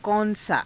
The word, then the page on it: kaun saa: Hindi